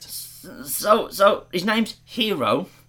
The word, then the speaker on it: Hiro: Honey Lemon, who insists on calling him Hiro. Giving it the Japanese pronunciation.